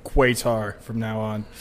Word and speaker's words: Qatar: Oh, so when the British had it, they pronounced it correctly as Qatar. Then they went on their own and switched it to Qatar.